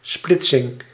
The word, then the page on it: division: /di.vi.zjɔ̃/